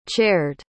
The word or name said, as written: chaired